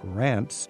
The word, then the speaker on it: Reims: How would you say it in French? – Reims. – Reims. – And then you have to pronounce the S at the end, which usually we don't. We don't say Paris, we say Paris. – Right. – But we say Reims, so it's the exception.